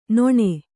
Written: ನೆಣ

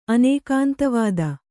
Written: ಅನೇಕಾಂತವಾದ

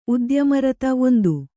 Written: ಉದ್ಯಮರತ1

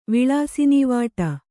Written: ವಿಳಾಸಿನೀವಾಟ

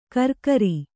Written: ಕರ್ಕರಿ

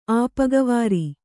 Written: ಆಪಗವಾರಿ

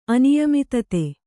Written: ಅನಿಯಮಿತತೆ

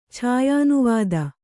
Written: ಛಾಯಾನುವಾದ

